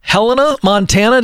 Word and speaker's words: Helena, Montana: Let's go to Sean, who's on the line in Helena, Montana.